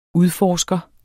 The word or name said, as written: udforsker